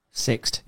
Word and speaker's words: Sixt: So it's Sixt. I think that's how you pronounce it. Is it Sixt? – Sixt. Yeah, I think I know the one you mean, yeah.